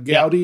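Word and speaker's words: Goudey: He had a 1933 Gaudy. Is that how you say it? Gaudy. Yep, the Ruth.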